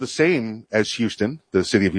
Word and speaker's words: Houston: but he pronounced it Houston the Scottish way.